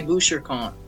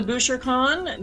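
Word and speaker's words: Bouchercon: Okay, so I'm Canadian, so I say Boucher-con. But Boucher-con? – Every time I go to Boucher, it's okay. Boucher-con, right? Every time I go to Boucher-con, the Americans always correct me and say it's Boucher-con because that's how he pronounced it. But if you were in France and you said Boucher-con, people would just like point at you and laugh.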